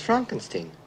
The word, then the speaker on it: Frankenstein: Frankenstein. – You're putting me on. – No, it's pronounced Frankenstein.